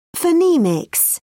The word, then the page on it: phonemics: British English